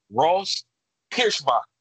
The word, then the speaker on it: Pierschbacher: My wife, she taught me how to say his name. I'm proud of this. Ross Pierschbacher. – We've been saying his name wrong for 2 years.